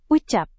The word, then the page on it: ucap: indonesian